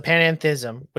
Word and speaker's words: panentheism: So you have pantheism and you have panentheism. The end there is different.